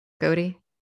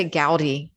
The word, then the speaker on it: Godey: Godey? – Yeah. – Okay. – Yep. – I always want to say Gowdy because that's like the American in me, I guess.